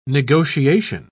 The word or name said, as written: negotiation